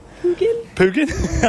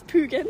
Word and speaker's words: Pugin: As the assistant to the talent, I will help with a little bit of pronunciation here. We're looking at. – Pugin? – Okay, yeah, that was probably that was as good as it gets.